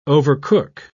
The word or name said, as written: overcook